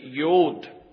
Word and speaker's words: Yod: Now you say, well, I don't see Yod there. I see J-O-D before the section. Surely it's Jod. Well, I'm reliably informed that the proper pronunciation is actually Yod.